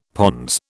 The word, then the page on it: pons: British English